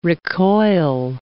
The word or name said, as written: recoil